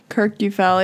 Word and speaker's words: Kirkjufell: Kirkjufell, yeah.